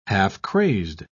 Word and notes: half-crazed: British English

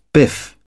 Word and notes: biff: British English

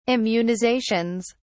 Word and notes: immunizations: British English

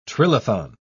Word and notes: trilithon: British English

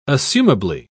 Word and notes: assumably: British English

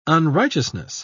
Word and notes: unrighteousness: British English

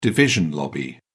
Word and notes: division lobby: British English

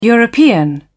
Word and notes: european: British English

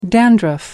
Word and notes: dandruff: American English

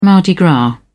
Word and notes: mardi gras: British English

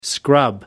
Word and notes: scrub up: American English

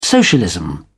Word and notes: socialism: British English

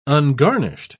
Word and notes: ungarnished: British English

